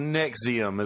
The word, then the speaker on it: NXIVM: It's pronounced Nexium, is what they're what people call it. But I say NXIVM, so that way V as in Victor.